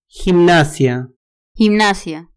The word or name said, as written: gimnasia